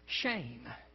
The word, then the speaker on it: Shem: He said, when I teach introductory Hebrew, the first word I typically teach is the common noun Shem. It's pronounced exactly like our English word shame. So it's spelled S-H-E-M, but it's pronounced shame.